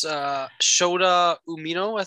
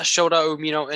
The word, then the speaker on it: Shota Umino: We'll move into the it's the Sex Gods versus Eddie Kingston, Wheeler Yuta, and then it's Shota Umino, I think that's how you pronounce his name. Mm-hmm. Yeah, Shota Umino in a 6-man tag match.